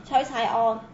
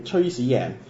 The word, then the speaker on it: 崔世安: 博士，正確讀音係乜嘢啊？ – 崔世安。 – 都錯，我教你。是崔始贏。